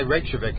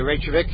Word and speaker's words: Reykjavik: Yes, and I can say Reykjavik because I pronounce the J. And if we have gotten some cards and postcard, even a floral bouquet of somebody, thank you, by the way, asking me to stop pronouncing the J. But I'd like, the J is there. What's it there for if you can't pronounce it? And I think that might be a valid way to pronounce Reykjavik.